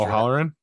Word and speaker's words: O'Halloran: I don't want to butcher O'Halloran? – Yes.